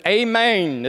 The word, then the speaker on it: amen: In the Greek, it is that Hebrew transliteration, A-M-E-N. Now, whenever Jesus prefaces what he's about to say with amen, is how you say it really in Greek, amen.